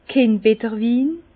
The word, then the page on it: Kindbetterwin: Bas Rhin; Herrlisheim